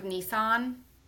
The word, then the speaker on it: Nissa: I think it's because of Nissan, maybe. And in the UK, they pronounce the car Nissan, and I think that people from the UK pronounce my name right.